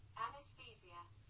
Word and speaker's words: anesthesia: In both, you know, according to what I'm listening to, both in the United States and in the, U- the UK, it's pronounced anesthesia, sonando la TH, el ceseo de la TH, inglesa suave, anesthesia, anesthesia, anesthesia, anesthesia.